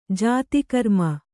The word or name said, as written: ಜಿತಕರ್ಮ